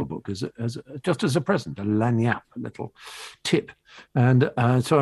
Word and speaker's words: lagniappe: Lagniap is spelled LAGNIAPPE, lagniap, but pronounced lagniap.